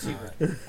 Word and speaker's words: Hubert: I wrote a U. Hubert. So it's Hubert. I just mispronounced it the first time.